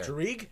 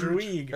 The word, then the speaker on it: Druig: I wasn't crazy about, I'm pronouncing the name wrong, Dirge? – Yeah. Druig? – Druig. Thank you. – Dirge. Come on, man. – Dirge. – Druig. Like Druid, but with a G.